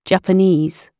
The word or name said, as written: japanese